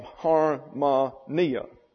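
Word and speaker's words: harmonia (ἁρμονία): Therefore, you have harmonia, accent on the next to the last syllable.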